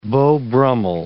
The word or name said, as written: Beau Brummell